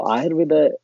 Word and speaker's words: Ayurveda: So Ayurveda, absolutely, you said it correctly.